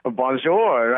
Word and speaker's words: bonjour: Bonjour.